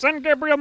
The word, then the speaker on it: San Gabriel: It's pronounced San Gabriel Mountains.